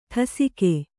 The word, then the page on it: ಠಸಿಕೆ: ṭhasike